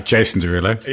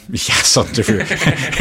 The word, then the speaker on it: Derulo: Yason Derulo. Yeah, it's pronounced Yason Derulo.